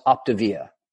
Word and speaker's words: Optavia: And that company is Optavia? – It's pronounced Optavia.